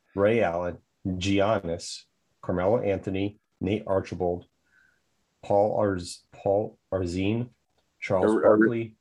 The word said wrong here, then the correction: Arizin: I thought it was Arizin, but yeah.